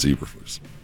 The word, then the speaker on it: Eberflus: Nobody Lena said it's Eberflus.